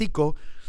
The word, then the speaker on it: Sico: It's called Sico, but that's how it's pronounced, Sico, but it's spelled S-I-C-O. So go to your Google satellite and search for S-I-C-O, comma, Honduras, H-O-N-D-U-R-A-S, Sico, Honduras.